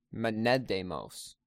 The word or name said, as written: Menedemos